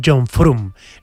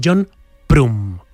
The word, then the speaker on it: John Frum: No vendría de ahí su nombre, y él me dijo, no, es que lo pronunciáis mal, no es John Frum, es John Prum, con P.